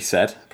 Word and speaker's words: Said: I'm going to talk about Phoenix by SF, I believe it's pronounced Said, could be said, pretty sure it's Said.